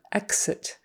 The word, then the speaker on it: exit: Exit. We usually say it with more of a GZ. Exit. Exit. I don't know why. I just know we do.